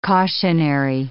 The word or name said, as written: cautionary